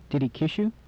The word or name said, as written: kiss you